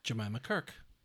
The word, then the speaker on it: Jemima Kirk: The cast includes Allison Oliver as Frances, Sasha Lane as Bobby, Joe Alwyn as Nick, and Jemima Kirk as Melissa. – Jemima Kirk.